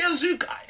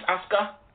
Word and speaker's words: Asuka: I believe it's Asuka. – How can I call her Asuka? Like Bazooka?